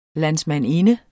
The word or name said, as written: landsmandinde